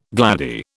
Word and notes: gladdie: British English

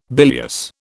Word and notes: bilious: British English

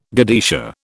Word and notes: godetia: British English

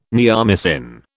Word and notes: neomycin: British English

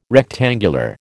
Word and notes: rectangular: American English

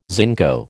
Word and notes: zinco: American English

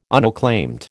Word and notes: unacclaimed: American English

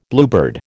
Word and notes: bluebird: American English

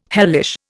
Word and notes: hellish: Indian

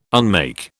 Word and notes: unmake: British English

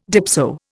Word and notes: dipso: Indian

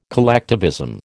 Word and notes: collectivism: American English

